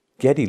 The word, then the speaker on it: Geddy: And it's funny because, man, Geddy Lee, who, his name's Gary, he called himself Geddy because his heavily accented mother pronounced Gary as Geddy.